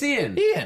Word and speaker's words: Iain: Is it Ian Glen? – I think it's Ian. – I-A-I-N is a weird way to say Ian, but okay.